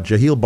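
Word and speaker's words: Jehiel: So he's passing this along, but his first name, the first name of the tailor was J-E-H-I-E-L. I don't know how to pronounce that properly. – Jaheel? – Yeah. Jaheel Barnard was Rochester's very first tailor in 1812.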